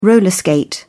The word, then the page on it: roller skate: British English